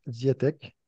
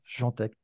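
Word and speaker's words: Świątek: Alors, je veux parler de Iga Ziatek, et non pas Jantek. Désolé pour la prononciation, je dirais Ziatek.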